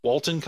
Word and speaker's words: Walton: Now, am I mad that Walter Coggins was in this? Walton Coggins? I'm going to say his name right eventually. I've noticed I've been saying it wrong lately.